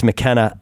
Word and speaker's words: McKenna: The term machine elf was coined by ethnobotanist Terence McKenna, I said it right, for the entities he encountered in DMT hyperspace, also using terms like fractal elves or self-transforming machine elves.